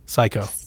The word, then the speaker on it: Psikyo: P-S-I-K-Y-O. – Psycho.